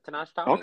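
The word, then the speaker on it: Tahnaj Thomas: 26, I have, oh man, I know I'm going to butcher it. Tanaj Thomas? – No, you said it right. Tanaj Thomas.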